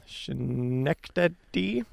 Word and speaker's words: Schenectady: Schenectady. – Yeah, that's you nailed it.